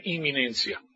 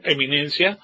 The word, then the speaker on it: inminencia: Todo esto lo vamos a ver con lujo detalle, porque es importante que entendamos el asunto de la inminencia. Se pueden dar cuenta que la inminencia se escribe I-N-M, ¿verdad? Inminencia, no eminencia, sino inminencia quiere decir que puede ocurrir en cualquier momento.